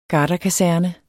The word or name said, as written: garderkaserne